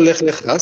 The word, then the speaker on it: Lech Lecha: Adesso andiamo su, scusi la pronuncia, Lech Lecha, giusto? – Perfetto, Lech Lecha, sì, sì.